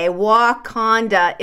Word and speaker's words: Wauconda: It's spelled W-A-U-C-O-N-D-A, Wakanda, Illinois. But like I said, they're receiving a lot of attention because it's pronounced very similar to Wakanda.